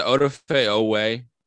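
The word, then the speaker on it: Odafe Oweh: Is it Odafe Owe? – Yes. – Odafe Owe. Okay.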